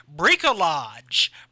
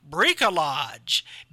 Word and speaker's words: bricolage: This week's weird word is bricolage. Bricolage. BRICOLAGE. I even looked up how to pronounce it phonetically, and that's how you say it.